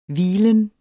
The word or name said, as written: hvilen